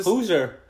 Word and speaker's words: Hozier: But, Wasted Land, Baby by Hozier. I think that's how you say his name. But he's a... – Hozier. – That's how you say it? Hozier? – Hozier.